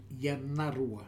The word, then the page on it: Yanarua: new form of Anarua